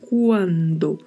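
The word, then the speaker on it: cuando: Muita atenção à pronúncia, porque é escrito com C-U-A, então vamos pronunciar quando. Né? Temos que pronunciar mesmo de maneira bem clara a letra U.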